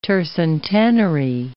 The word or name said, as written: tercentenary